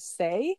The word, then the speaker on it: Saay: Her name is Say. It's spelled S-A-A-Y, but there's a song she has called From the Horizon that I just have been loving quite a lot.